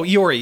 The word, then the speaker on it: Iori: Am I pronouncing his name wrong? – Oh, Iori. Yeah, Iori. Yeah.